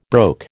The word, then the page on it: broke: American English